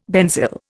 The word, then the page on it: benzyl: Indian